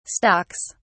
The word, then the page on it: stocks: British English